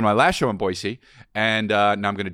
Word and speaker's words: Boise: And then May 19th, I'm going to be in Boise. And that's how you say it. And whoever told me that was Aaron Paul. Aaron Paul told me. – Well, he's from there. – It's Boise.